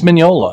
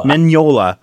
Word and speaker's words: Mignola: And this is really, and I don't know if, and I don't even know if I'm pronouncing his name right. Mignola? – I believe it's Mignola. – Mignola.